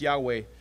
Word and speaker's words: Yahweh: All right, David calls out to God with the Hebrew name, which we pronounce Yahweh. It's the word you'll see in your text that's translated as capital L-O-R-D, but the O-R-D are a little bit smaller.